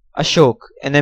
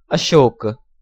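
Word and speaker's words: Ashok (अशोक): Next row, Hindi or Sanskrit word, Ashok, an emperor. Usual transliteration, A-S-H-O-K-A or A-S-O-K-A. Sanskrit pronunciation, Ashok. Hindi pronunciation, Ashok. Foreigner's pronunciation, Ashoka or Ashoka.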